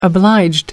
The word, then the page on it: obliged: American English